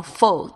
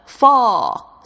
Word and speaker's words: full: 你会听到这个美国人呢，或者英国人呢，他们会用 full 这个字，这个 full。哦，跟那个秋天那个 fall 是不一样的发音，但是呢，嘴巴稍微小一点，但是也不是完全是扁平的状况。